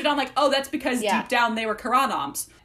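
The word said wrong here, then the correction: Carranam: Sorry, I gotta say that right. What is it? – Karanom. – Thank you.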